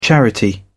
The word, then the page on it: charity: British English